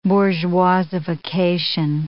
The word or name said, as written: bourgeoisification